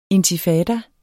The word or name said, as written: intifada